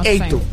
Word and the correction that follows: feito: Eito, no se dice eito. Hecho se dice así como, lo que es predicita. Feito. Feito.